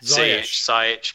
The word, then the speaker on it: Ziyech: Ziyech.